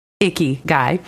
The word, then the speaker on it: ikigai: And actually, you share the word ikigai. I don't know if I'm saying that right. – You, yes. you even say in the book, pronounced like icky guy, um, which roughly means the reason you wake up in the morning.